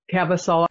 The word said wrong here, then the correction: Caviezel: I can't pronounce his name correctly. Anyway, another Caviezel. Rabbit. Caviezel. Thank you.